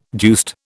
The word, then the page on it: deuced: British English